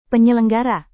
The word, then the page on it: penyelenggara: indonesian